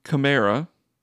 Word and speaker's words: Kamara: So first off, it's Alvin Kamara, as verified by Alvin Kamara himself.